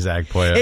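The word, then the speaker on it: Pollo: It should be Zach Poyo. – It should be because it's P-O-L-L-O.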